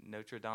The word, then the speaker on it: Notre Dame: they're talking about the Notre Dame. – Mm-hmm. – Is that how you pronounce it? – Yeah.